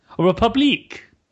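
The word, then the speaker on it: République: I always try to pronounce this very French. – Republique? – Yeah.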